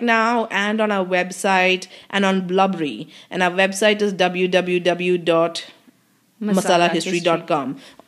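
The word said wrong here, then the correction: Blubrry: Also, it's not Blubbery. It's called Blueberry, but without the E. – How do you even pronounce that? – It's apparently Blueberry.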